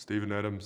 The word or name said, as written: Steven Adams